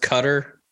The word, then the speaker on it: Qatar: We talk about stuff that it's pronounced Cutter, by the way. – Cutter, Qatar.